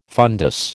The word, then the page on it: fundus: American English